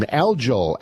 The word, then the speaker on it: algal: So anyways, we call it algae blooms, but it's algal blooms.